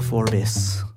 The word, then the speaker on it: Forbes: I was like, is it Will Forbes or Will Forbes? And he's like, two syllables. – Forbes. – So is it, I hope it's not bees versus biz. Will Forbis.